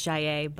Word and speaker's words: Gillette: In French, it's Jaillet, but no one would get it right. So we just go with Gillette.